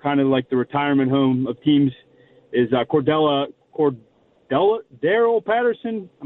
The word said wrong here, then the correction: Cordarrelle Patterson: Cordarrelle Patterson.